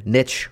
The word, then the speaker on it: niche: But again, niche rhymes with rich, so let's stick with that. That's why I pronounce it that way.